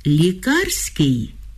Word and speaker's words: лікарський: Треба пам'ятати, що крім прикметників «лікарський», «лікувальний» і «лікарняний», є ще прикметник «лікарський» із наголосом на другому складі.